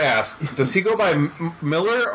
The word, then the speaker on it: Millar: In Scotland, it's Miller. Over here, we don't really make a distinction if it's spelled E-R or A-R at the end. So you've got Frank Miller and Mark Millar. Over in Scotland, everybody's just Miller. – Gotcha. – It's just one pronunciation.